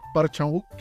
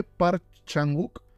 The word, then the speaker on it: Park Chan-wook: Pero es un tema con los nombres coreanos porque se escribe Park Chan-wook y creo que se pronuncia Bak Chan-wook, algo así.